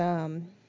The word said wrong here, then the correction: Aloy: Aloy. – Aloy. See, I was saying her name wrong.